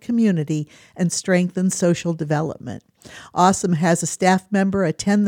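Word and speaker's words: AuSM: It's A-U-S-M, pronounced awesome.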